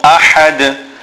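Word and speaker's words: ahad (أَحَد): And then in the word ahad, the ha is a big ha which must be pronounced from the center of the throat.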